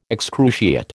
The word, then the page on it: excruciate: American English